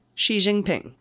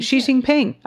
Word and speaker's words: Xi Jinping: How do you say it? Did I do that right? – Xi Jinping. – Look at me. All right. Xi Jinping. I got one right.